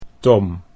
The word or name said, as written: Tom